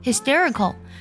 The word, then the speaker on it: hysterical: 然后呢，T E R I C A L，hysterical，hysterical，要注意一下 t 呢要被浊化啊。 hysterical，它本身呢有着情绪异常激动、歇斯底里的意思。除了这两层意思呢，还有极其可笑的意思。比如说，每次呢我看到崔娃的这个脱口秀的时候，觉得啊，he's hysterical，